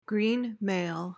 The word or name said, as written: greenmail